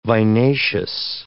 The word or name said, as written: vinaceous